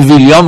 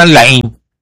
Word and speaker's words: ویلیام: این آقایون به ویلیام میگفتن لعین، هیچ اسم خارجی رو درست بلد نبودن تلفظ کنن، بودن لعیم اول، لعیم دوم، لعیم سوم.